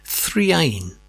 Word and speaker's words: 3'ain: And a trio with an unusual name, Threain. That's 3'AIN. It's a name based around an Arabic letter, which in shape is similar to the number 3, but backwards, and phonetically pronounced ain.